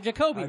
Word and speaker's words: Jacoby: How do you say Jacoby? Is it Jacoby, right? – Yeah, just like you said it.